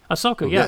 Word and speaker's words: Ahsoka: Ahsoka, yeah.